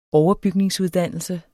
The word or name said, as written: overbygningsuddannelse